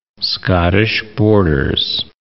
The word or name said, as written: Scottish Borders